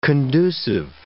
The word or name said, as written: conducive